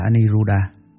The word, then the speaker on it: Aniruddha: Ngài An Nậu Lâu Đà, Ấn Độ đọc là Aniruddha, chữ Hán là Vô Bần.